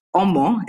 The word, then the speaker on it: Omo: So basically, Omo, although I'm saying it in the Oyibo way, Omo is how to pronounce it.